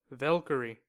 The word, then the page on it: valkyrie: American English